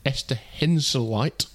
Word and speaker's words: Esther Henseleit: It was her first pro win on home soil, and she beat Germany's Esther Henseleit. Have I said that right? I think so, yeah.